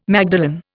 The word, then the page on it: magdalen: Indian